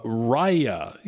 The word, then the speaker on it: Raya: So Raya is how you pronounce it.